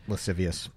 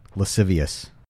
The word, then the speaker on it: lascivious: Facing lewd, lavicious, and lavicious battery charges. – Lascivious. – What did I say? – Lavicious. – That sounds a lot better. How do you say it? – Lascivious.